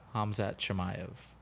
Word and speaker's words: Khamzat Chimaev: So we have that Khamzat Chimaev, Paulo Costa fight coming up. – Khamzat Chimaev. – I said those two back, the first syllable backwards on both of them. So the backup for that fight is Kamaru Usman.